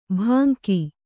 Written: ಭಂಗಿ

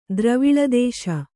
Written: ದ್ರವಿಳದೇಶ

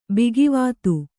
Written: ಭಾಗವತ

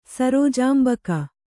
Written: ಸರೋಜಾಂಬಕ

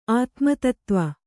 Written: ಆತ್ಮತತ್ತ್ವ

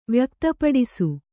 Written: ವ್ಯಕ್ತಪಡಿಸು

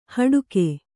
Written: ಹಡಕೆ

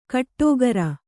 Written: ಗುಟ್ಟುಗಾರ

